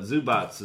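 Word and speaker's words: Zubac: I am a proud owner in 30-man for Ivanka Zubats. Like robots, but Zubats is the pronunciation.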